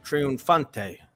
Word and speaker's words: Triunfante: So moving down the line here, we have the Triunfante. I think I said that right. – You said it right, and as Jeff says, when he's sending me orders, it's the triumphant, and that's what triunfante means in English.